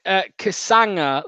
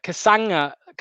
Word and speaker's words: Kasanga: Ben says it's pronounced Kasanga, like lasagna. Kasanga.